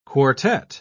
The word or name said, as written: quartet